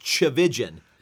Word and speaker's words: Chavijan: It's pronounced Chavijan. – Chavijan. – And it rhymes with religion.